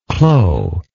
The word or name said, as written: clos